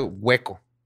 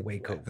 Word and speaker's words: Waco: Y se pronuncia hueco, como si estuviera hueco. – No, hueco. No, es W-A-C-O. Pero le dicen Waco.